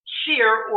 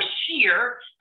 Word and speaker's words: Cyr: We'll cut Serour in half. We'll make that Cyr or Cyr, and we'll spell it C-Y-R because we're in Quebec.